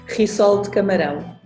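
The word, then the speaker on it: rissol de camarão: Help her with the pronunciation, please. – Risol kamarau. – Okay, it's the same. – See? – It's more or less the same. – Yeah, I got it right.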